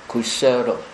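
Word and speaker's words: kuśala: 应该念成 kusala，就那个 s 上面有一撇，就是读成 sh，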